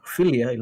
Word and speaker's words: philia: Yeah, philia, like, you know, like philosophy, you know, that kind of thing.